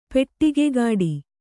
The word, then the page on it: ಪೆಡಗೈಗಟ್ಟು: peḍagaigaṭṭu